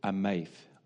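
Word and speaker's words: ameth (עמך): The Hebrew word there is ameth, ameth.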